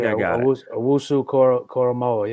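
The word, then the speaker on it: Owusu-Koramoah: Jeremiah Owusu-Koramoa. I think Owusu-Koramoa, yeah.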